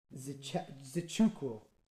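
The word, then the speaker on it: Nzechukwu: Oh, I actually wrote down how to pronounce this. I'm not going to butcher it, dude. I swear to God. I feel like we can make, like, a whole compilation of me just butchering people's names on this pod because of just how many tough ones there are to say nowadays. Because I mean no disrespect. That's why I try so hard to pronounce them right. Zichukwu. Got it.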